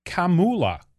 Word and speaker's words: Kamala: My name is pronounced Kamala, like the punctuation mark.